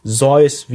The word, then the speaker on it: Seuss: Tatsächlich spricht man Dr. Seuss gar nicht Dr. Seuss richtig aus, sondern es heißt eigentlich Seuss wie in Voice.